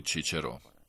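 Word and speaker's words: Cicero: Ovdje se ukazuje i na čuvenog starogrčkog filozofa Ciceroa, čije ime Turci izgovaraju Čičero.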